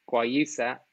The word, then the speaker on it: Guayusa: New Infusions is Ireland's first guayusa tea company. Guayusa,